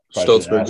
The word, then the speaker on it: Shtolzberg: Shtolzberg, yeah.